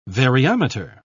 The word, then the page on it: variometer: British English